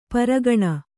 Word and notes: ಪರಗಣ: paragaṇa

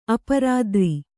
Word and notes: ಅಪರಾತ್ರಿ: aparātri

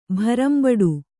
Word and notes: ಭರಂಬಡು: Kannada

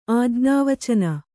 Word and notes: ಆಜ್ಞಾವಚನ: Kannada

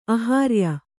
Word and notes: ಆಹಾರ್ಯ: Kannada

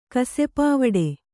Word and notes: ಕಸೆಪಾವಡೆ: kasepāvaḍe

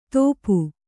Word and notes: ದೋಪು: Kannada

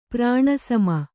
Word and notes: ಪ್ರಾಣಸಮ: prāṇa sama

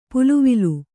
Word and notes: ಪಲವಲ: palavala

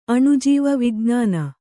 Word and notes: ಅಣುಜೀವಿ: aṇujīvi

